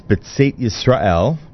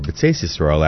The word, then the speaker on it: Betzeis Yisrael: That was Betzeit Yisrael, or Betzeis Yisrael, actually, they use that pronunciation.